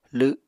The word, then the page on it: lw7: Apatani